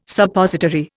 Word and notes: Suppository: Indian

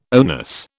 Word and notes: oneness: British English

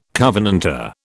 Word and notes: covenanter: British English

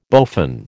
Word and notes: bowfin: American English

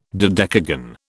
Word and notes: dodecagon: British English

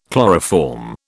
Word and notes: chloroform: British English

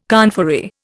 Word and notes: conferee: Indian

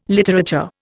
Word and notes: literature: Indian

